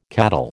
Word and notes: cattle: American English

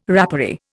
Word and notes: rapparee: Indian

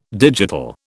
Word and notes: digital: British English

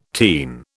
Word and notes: teen: British English

